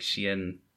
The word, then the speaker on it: Cienfuegos: Cien, I'm pretty sure it's like it, it's a it's a soft C, so it's like Cienfuegos.